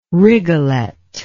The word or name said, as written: rigolet